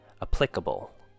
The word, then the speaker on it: applicable: And our last one for pronunciation would be applicable. 4 syllables. Then pl. I would say this is a short I sound, followed by k, short U sound, and bull, like the animal bull. Applicable.